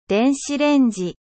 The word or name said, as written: micro-onde